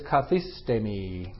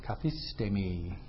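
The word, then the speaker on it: kathistemi (καθίστημι): So what we have is kathistemi, kathistemi. K-A-T-H-I-S-T, long E-M-I. Kathistemi.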